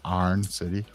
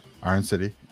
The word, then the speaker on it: Iron City: And they're doing some, Iron City and proper pronunciation, Arn City, right? – Arn City.